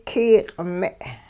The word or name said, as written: kiyiq'-me'